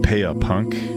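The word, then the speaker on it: Pære Punk: But there's a great compilation of Danish punk from that late 70s into the early 80s era called Pæa Punk, which is spelled P-A-E-R-E Punk.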